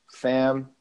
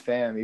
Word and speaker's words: Pham: So Pham, I choose Pham, even though the correct way to pronounce it, I'm half Vietnamese, so the correct way to pronounce it is Pham. But I say Pham because I think I like to say that everybody's my family, and I'm like a walking example of Southern hospitality, so I use Pham.